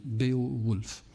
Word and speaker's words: Beowulf: Beowulf, ça s'écrit, pour ceux que vous connaissez pas l'œuvre, B-E-O-W-U-L-F. Donc, ça s'écrit, comment ça se dit ? Beowulf.